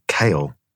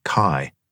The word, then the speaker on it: Caille: His name was Jean-François Caille, which I mispronounced, calling him Kale, as I hadn't learnt yet that the French use plenty of letters just for their looks. And it was properly pronounced Caille, as in Kyle, but without the L.